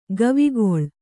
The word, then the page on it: ಗವಿಗೊಳ್: gavigoḷ